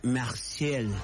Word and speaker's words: Marseille: Toulouse se pronuncia Toulouse, y Marsella se pronuncia Marseille.